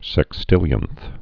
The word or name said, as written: sextillionths